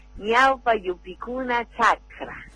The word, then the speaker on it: Ñaupa Yupicuna Chakra: Ella me dirá si lo pronuncio bien o no. Ñaupa Yupicuna Chakra. ¿Es así, profe? – Ay, muy bien, hermano, muy bien pronunciado. Ñaupa Yupicuna Chakra.